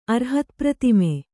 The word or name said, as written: ಅರ್ಹತ್ಪ್ರತಿಮೆ